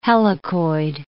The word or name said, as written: helicoid